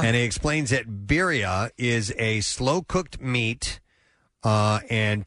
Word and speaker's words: birria: Birria taco. – The birria taco? – B-I-R-R-I-A is how you spell that.